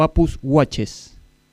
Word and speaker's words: Apus Watches: ¿Es Apus Watch o Apus Watches? – Sí, Apus Watch, pero bueno, se escribe Apus Watches, Ah, bien.